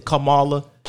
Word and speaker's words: Kamala: He pronounced her name correctly. – He said, Kamala.